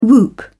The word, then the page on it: whoop: British English